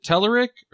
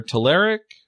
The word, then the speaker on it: Telerik: So is it Telerik or Telerik or Telerik? – Telerik and Telerik are both proper pronunciations, which is crazy.